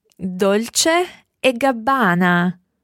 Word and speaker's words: Dolce e Gabbana: What about the other one that's spelled P-R-A-D-A? – Prada. – Prada. – And then the one that's two people's names? – Dolce e Gabbana.